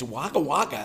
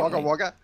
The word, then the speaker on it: Wagga Wagga: So, and it's Wagga Wagga, if I remember correctly. – Is that yeah, right? Wagga Wagga. Yeah, Wagga yeah. Wagga. – Spelt Wagga Wagga, but it's but pronounced Wagga Wagga